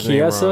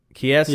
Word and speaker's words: Chiesa: I think I pronounced his Chiesa? Name wrong. Chiesa.